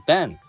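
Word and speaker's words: vin: あの、ね、あの、ワインのことをフランス語で、あの、バンって発音するんですけれどもね。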